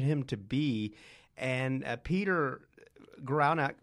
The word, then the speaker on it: Guralnick: Guralnick, yeah.